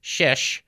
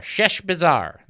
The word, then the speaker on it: Sheshbazzar: Let me say it right. S-H-E-S-H. Sheshbazzar. Sheshbazzar. That's it. Sheshbazzar. Almost like Chef Bazaar, but Sheshbazzar.